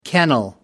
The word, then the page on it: kennel: American English